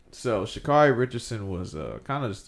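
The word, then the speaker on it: Sha'Carri: Shacarri Richardson. Is that how you say her name? – Yeah.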